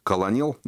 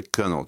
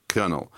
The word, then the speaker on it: Colonel: Кстати, Colonel, хотя пишется это колонел, но это Colonel читается. Colonel.